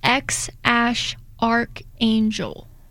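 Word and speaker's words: X Æ A-Xii: The baby's name is supposed to be pronounced X Ash Archangel. – Oh, boy. Artsy. – Mm-hmm. – X Ash Archangel.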